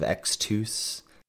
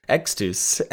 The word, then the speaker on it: Extus: You love to make the US sound oos, like Rise of Xtus. – Is it not that? Are you returning as the Lords of Limited Pronunciation expert? – I have to step in here and say it's Grinning Ignus. – What am I saying? Ignus? Xtus? – Extus, Extus, Ignis.